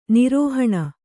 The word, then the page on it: ನಿರೋಹಣ: nirōhaṇa